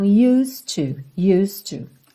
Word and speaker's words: used to: Você não fala used, esse é não é pronunciado. E o D já faz um som ligadinho no T do to. Então, used to, ok?